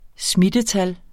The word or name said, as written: smittetal